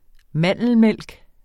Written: mandelmælk

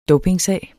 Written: dopingsag

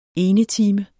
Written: enetime

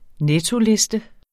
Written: nettoliste

